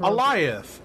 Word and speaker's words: Alioth: Eliath. Eliath. Like okay. Goliath. Like Goliath. Yeah, Goliath without the G.